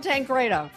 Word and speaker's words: Tancredo: Tom Tancredo.